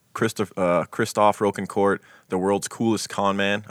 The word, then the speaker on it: Rocancourt: Would you call it Rocancourt or Rocancourt? The documentary I watched said Rocancourt. – I would say Rocancourt. I would say long O there.